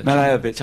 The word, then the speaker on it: Malevich: It's Kazimir Malevich, or how do you pronounce his name? – Malevich.